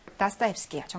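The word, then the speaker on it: 다스타옙스키예요 (Достоевский): 그래서 빈칸에 들어갈 것은 누구의 까라마조프가의 형제들이냐, 작가가 누군지를 묻고 있는 건데, 제가 말한 것처럼 다스타옙스키예요.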